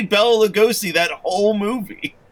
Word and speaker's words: Lugosi: How do you pronounce that last name? – Lugosi? – I think it's Lugosi. – It is Lugosi.